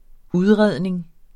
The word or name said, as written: udredning